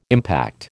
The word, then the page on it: impact: American English